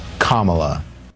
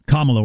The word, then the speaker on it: Kamala: Say it with me Kamala. – Kamala.